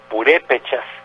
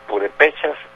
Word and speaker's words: purépechas: el vocablo purépechas, y nos comentaba un, una gente que sabe, dice, pueden decir purépechas o purépechas, como se dice Tenochtitlan o Tenochtitlan Entonces hago esta aclaración porque más de alguien va a decir, éjele, es purépechas, no purépechas.